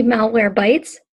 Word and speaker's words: Malwarebytes: Malwarebytes checkbox not checked. – Woo, that butchered that name. That would be Malwarebytes.